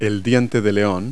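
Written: dandelion

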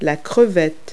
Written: shrimp

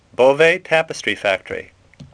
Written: Beauvais

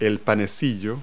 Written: roll

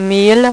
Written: mille

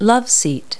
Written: loveseat

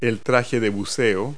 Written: wetsuit

